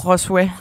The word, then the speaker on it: Josué: Og, jo, Joshua hedder han. Han hedder ikke José. Vel? Nej, Josué. Josué.